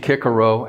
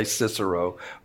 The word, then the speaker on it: Cicero: The Romans, according to the orator and politician Cicero, and I said Cicero, yes, I know we all say Cicero, but pronunciation-wise, C should be a hard K.